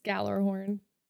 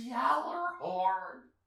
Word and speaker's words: Gjallarhorn: This is Gjallarhorn. – Oh, for the love of... For the last time, that's not how it's pronounced. It's pronounced Gjallarhorn.